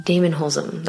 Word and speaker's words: Damon Holzum: Damon Holzum. That's how I say your name, right? – Perfect.